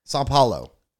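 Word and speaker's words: São Paulo: Well, this one's live in, did you say Sao Paulo? How you say it? Sao Paulo? – Let me see. Sao Paulo. – Sao Paulo, okay.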